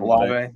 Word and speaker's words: Olave: I have Treylon Burks at number one, um, Drake London at number two, Garrett Wilson at three, Jameson Williams at four, Chris Olife. I, I know I said that wrong. – Olave, yeah, he hates, yeah, he hates Ohio State fan.